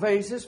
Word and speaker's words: vase: or we can get real country or real city like and say vase. I can't even say it right. Vase. Is it still vase around here? Okay. It's a vase. Oh, look at this beautiful vase of flowers.